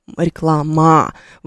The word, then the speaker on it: реклама: Она будет, вы не будете здесь произносить реклама. Вы произносите реклама. Там такой, знаете, неопределенный гласный звук, который в английской фонетике обозначается как перевернутое «е», если вы получили когда-нибудь английский язык и использовали фонетические значки.